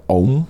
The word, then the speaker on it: Om: Het wordt uitgesproken als een enkel lettergreep om, maar in het Sanskriet wordt het geschreven als A-U-M, dus aum.